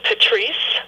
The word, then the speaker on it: Patrice: So my main hub is my website, and my first name is Patrice, spelled like Patrice Rushen's first name, P-A-T-R-I-C-E.